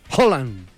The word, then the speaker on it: Haaland: ¿Cómo se dice, amigo? – Haaland. – Pero es que suena mejor Haaland. – Pero es que lo pero no es como suene bonito, sino como se diga, ¿no? – ¿Cómo que es correcto?